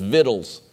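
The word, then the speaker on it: victuals: So you laugh at me all you want, but the proper pronunciation of this next word is vittles. Study it yourself. You can think it's just Beverly Hillbilly stuff, but that's exactly how you say it.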